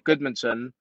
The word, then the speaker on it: Guðmundsson: which is kind of like, and then also the D in like Goodmanson, like it's G U, like for you it's easier to just spell it G U D but it's actually GU, and then there's a letter called if.